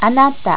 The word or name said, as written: anata